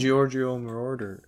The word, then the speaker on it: Giorgio Moroder: Think Giorgio Moroder.